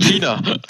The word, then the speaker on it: China: Ich bin noch ein bisschen perplex, weil du hast China falsch ausgesprochen. – Das heißt China.